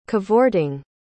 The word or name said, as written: cavorting